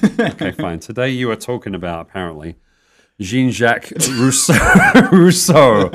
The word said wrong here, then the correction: Jean-Jacques Rousseau: That is not how you pronounce it.